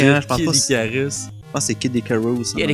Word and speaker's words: Kid Icarus: Ben, oui, vas-y, Moi, mon jeu que j'ai choisi, c'est Kid Icarus. – Ouais. – Oui. – Ça le prononce mal, je sais que c'est Kid Icarus en anglais.